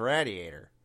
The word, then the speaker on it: radiator: and he has pigeons roasting on his radiator. – I'm sorry, did you say radiator? – Wasn't that the radiator of the car? – Radiator. Raid, not rad. – That's kind of like a location-based pronunciation.